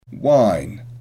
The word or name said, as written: wine